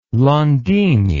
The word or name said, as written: Landini